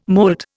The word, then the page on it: molt: Indian